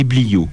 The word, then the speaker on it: bliaud: Alors les tuniques, chins et bliots. Chins, ça s'écrit C-H-A-I-N-S-E et bliot, B-L-I-A-U-D. Depuis le Xe siècle, les deux pièces principales de l'habillement des hommes et des femmes sont le chinze et le bliot.